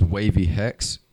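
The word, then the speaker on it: Wxvey Hex: Okay, so all social medias, my name is Wavy Hex, but it's spelled WXVEY. HEX, and that's on like all socials, YouTube, Instagram, Facebook, SoundCloud, Snapchat, yeah, everything, all platforms.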